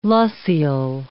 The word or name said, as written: loessial